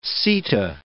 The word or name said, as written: seta